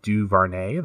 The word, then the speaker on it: DuVernay: And last but certainly not least, Ava DuVernay. That's how you say it? – Yeah.